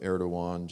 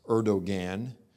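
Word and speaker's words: Erdoğan: His name is spelled with a G, so it's like pronounced Erdogan, but it's pronounced Erdogan so if you have a hard time remembering that, just think Star Wars, Erdogan Ben Kenobi. It sounds like Obi Wan. It, sound, it, it, rhymes with Obi Wan Ben Kenobi.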